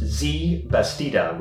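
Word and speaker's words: Xiye Bastida: Yeah, I believe that this is pronounced Z Bastida, which you can find your Twitter handle at XIYEBASTIDA.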